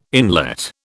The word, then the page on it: inlet: British English